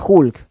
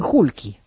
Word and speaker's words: Hulk: Era Hulk. Pero dicen Hulky.